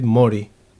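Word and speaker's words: Māori: Maori.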